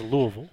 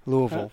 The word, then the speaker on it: Louisville: And against good teams in Alabama and Louisville, and then a fringy Tennessee team that should be better than it is. – I believe it's pronounced Louisville. – Louisville.